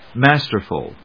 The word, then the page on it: masterful: mas・ter・ful; mˈæstɚf(ə)l ｜ mάːstə‐